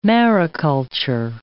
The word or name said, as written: mariculture